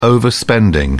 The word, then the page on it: overspending: British English